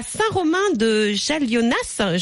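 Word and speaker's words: Saint-Romain-de-Jalionas: C'est à Saint-Romain de Jallionas. J'espère que je prononce bien le nom. – Oui, dans l'Isère.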